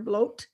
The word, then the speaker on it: blót: Okay, similar to the sacrifice, you'll hear a lot of times in Norse mythology the term blót, rhymes with goat, blót. Okay. And if you look at that, B-L-O-T, that looks like blood.